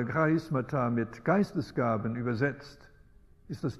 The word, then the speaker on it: Charisma: Übrigens nicht Charisma, sondern das griechische Wort beginnt mit einem Ch, mit einem Buchstaben, der wie das deutsche CH ausgesprochen wird. In der Mehrzahl lautet das Wort Charismata und deshalb der Ausdruck charismatisch, wonach die Bewegung genannt wird, weil sie so starke Betonung legt auf diese Begabungen.